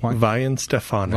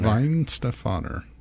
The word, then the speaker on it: Weihenstephaner: Weihenstephaner. – Weihenstephaner.